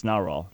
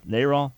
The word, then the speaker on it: NARAL: I don't think it's NARAL. NARAL, right pronunciation.